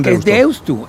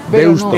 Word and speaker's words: Deusto: ¿Qué haces con Deusto? – Es Deusto, porque se escribe con U final. – Deusto.